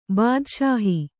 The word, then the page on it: ಬಾದಷಾಹಿ: bādaṣāhi